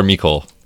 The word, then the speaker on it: Mecole: Is that how you say it, Mecole or Mecole? – Yes, Mecole. That's what I thought.